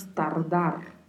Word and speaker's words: tardar: No verbo tardar, atenção ao vibrato dos R's. Em espanhol pronunciamos tardar.